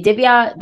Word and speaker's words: Divya: They can say Divya.